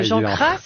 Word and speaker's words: Jean Cras: Oui, mais j'arrive pas à bien faire la fin de Jean Cras, mais il faudrait le faire entre le S et le Z, d'après ce qu'on a compris.